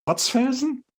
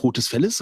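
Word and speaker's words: Rotes Velles: R-O-T-E-S. Zweites Wort. V-E-L-L-E-S. – Rotzfelsen? – Du hast es fast richtig ausgesprochen. Also nicht Rotes Felles, sondern Rotzfels.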